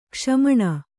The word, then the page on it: ಕ್ಷಮಣ: kṣamaṇa